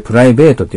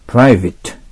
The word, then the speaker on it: private: これプライベートって読まないのにね。 private。